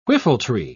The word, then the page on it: whiffletree: British English